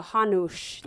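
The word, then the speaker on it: Hanuš: How do you say the S with the little... Okay, so Hanush. Yes.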